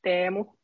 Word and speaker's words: Teemu: And also, it's Teemu. So if you want to practice it, go ahead.